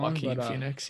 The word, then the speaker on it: Joaquin Phoenix: And honestly, I saw the latest Joker with I think his name's Joaquin Phoenix. I might be saying that wrong. – Joaquin Phoenix, yeah.